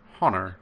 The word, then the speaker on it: honor: There's a silent H in some American, like honor and herb, and there's a couple more, like hour.